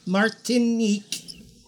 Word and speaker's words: Martinique: Martinique room 2509. – User phonics. – This is a travel agent. She literally just said Marque instead of Martinique, and then was like Martinique.